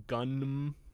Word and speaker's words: Gunnm: It is based on a manga by Yukito Kishiro, came out in the early 90s, originally called Gunnm, G-U-N-N-M.